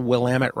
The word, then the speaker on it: Willamette: Took me, I think, 3 years to learn how to spell Willamette right.